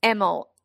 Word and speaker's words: Emil: It's spelled Emil, E-M-I-L. He pronounces it Emil. He gets out of the limo and he says to Andy, he says, it's anal, but with an M.